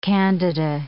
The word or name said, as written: candida